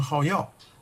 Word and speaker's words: 好乐: 有所好药好乐，读成好药，则不得其正。